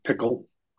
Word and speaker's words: Pkl: And Apple introduces a new programming language called PKL or Pickle. Not sure how you pronounce it. – Yeah, it's Pickle.